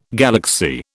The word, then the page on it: galaxy: British English